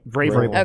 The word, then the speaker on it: Vrabel: Vrabel.